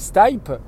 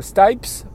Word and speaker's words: Stipe: Donc le chanteur, ceux qui connaissent le rock euh, et qui connaissent euh, en gros euh, ce monde-là, bah, tout le monde sait que le chanteur s'appelle Michael Stipe. S-T-I-P-E, donc en termes de, prononciation, il n'y a, pas trop de pièges. Et l'animateur, à deux ou trois reprises, Michael Stipes, Michael Stipes, Michael Stipes.